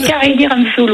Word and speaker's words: Kalindi Ramphul: Je crois que c'est Kalindi Ramphul, ouais. – Ah, c'est ça. Bon, bah, j'ai bien prononcé.